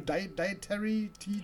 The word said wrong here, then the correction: Hereditary: Hereditary. – Ja, Hereditary, so.